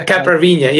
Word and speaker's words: caipirinha: Oh, the caipirinha. Yeah, I probably mispronounced it. Yes, yeah, okay. Yeah. How do you say it? Caipirinha? – Caipirinha. – Caipirinha. Wow, look at that. See? – Caipirinha, exactly.